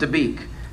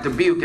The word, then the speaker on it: Dabiq: They believe that there's going to be a final showdown in a city named Dabiq. I have to be careful how I pronounce that, because one time it sounded like Dubuque and people freaked out.